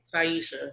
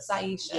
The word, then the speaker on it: Syesha: How do you spell it again? – S-Y-E-S-H-A. – Saisha. – Saisha.